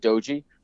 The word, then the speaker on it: Doge: Like people, he calls it doji, but it's spelled D-O-G-E.